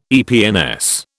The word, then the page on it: EPNS: British English